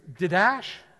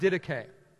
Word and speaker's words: Didache: I'm like, um, I don't think that's how you say it. Uh, I, I think it's Didash. You know, that's what I said. It's the Didash. It's, it's pronounced Didache, okay?